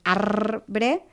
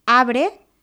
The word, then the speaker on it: arbre: En mots com arbre, cartró, prendre, més els seus derivats, aquesta R subratllada, de arbre, tant podem pronunciar-la com no. I, per tant, també podem dir abre, prendre o aprendre.